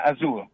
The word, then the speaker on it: Azul: And the music that you have most recently put out, the CD called Azul, is that how do you say that? – Yeah, Azul.